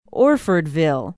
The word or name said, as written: orfordville